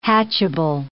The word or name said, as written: hatchable